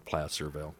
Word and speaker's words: Placerville: I'm going to pronounce it Placerville because I think placer is the type of mining, and you would pronounce that placer mining.